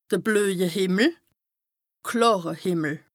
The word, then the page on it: de blöje Hìmmel / klàre Hìmmel: Bas-Rhin; Strasbourg